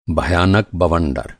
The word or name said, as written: बवंडर